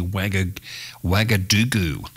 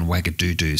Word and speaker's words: Ouagadougou: And I've just been told that I did make a slight pronunciation error in the capital city, Ouagadougou, there. It's actually Ouagadougou rather than Ouagadougou. So the capital city of Burkina Faso is actually Ouagadougou and not Ouagadougou, like I said.